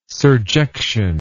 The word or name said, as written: surjection